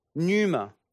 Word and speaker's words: pneuma: And in the New Testament, in the Greek, the word Holy Spirit or Spirit is pneuma. Now, for those of you who haven't seen that word before, the P is silent, pneuma.